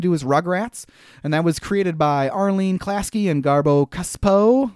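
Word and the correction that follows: Gabor Csupo: Gabor Kuspo.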